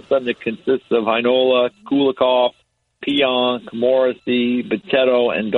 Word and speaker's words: Heinola: I mean, they got Ville Heinola, who is Heinola, I guess you would know how to pronounce it better. You called their game. – Heinola. – Heinola.